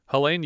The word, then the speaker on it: Helene: Helene Udy, or Helen Udy, I don't know how you pronounce this name, it's Helen with an E at the end. Helene. Helene Udy played Sylvia.